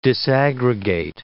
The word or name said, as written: disaggregate